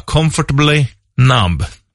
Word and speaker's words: Comfortably Numb: Jag tänkte att du ska få höra Comfortably Numb.